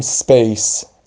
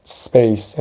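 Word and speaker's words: space: یه نکته بگم، این اسی که اول کلمه میاد بعدش یه کلمه بی صدا میاد ساکن تلفظ میشه، یعنی درست نیست بگیم سپیس یا بگیم اسپیس، بگیم سپیس. سپیس یعنی ساکن تلفظش کنیم. سپیس.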